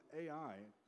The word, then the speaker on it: Ai: town of Ai, but it's spelled A-I, they lost profoundly.